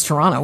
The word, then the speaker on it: Toronto: I mean, anybody who lives in Toronto knows it's Toronto. We don't pronounce that second T.